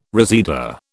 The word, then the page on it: reseda: British English